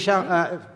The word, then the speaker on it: Chalamet: Hilariously, I went into it and totally didn't realise that it was a musical and was pleasantly surprised when Timothy Chalamet just started singing on top of a boat. So I also enjoyed it. – Can Timothy Chalamet...